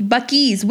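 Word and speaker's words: Buc-ee's: They need to be like Bucky, or is that how you say it? Bucky? – Bucky's, which is coming to Missouri, by the way.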